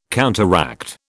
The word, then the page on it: counteract: British English